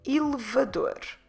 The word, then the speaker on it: elevador: Elevador. Elevador. Elevador. Como se escreve elevador? E-L-E-V-A-D-O-R.